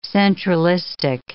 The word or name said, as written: centralistic